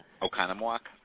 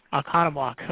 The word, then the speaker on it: Oconomowoc: Oconomowoc. – Oconomowoc.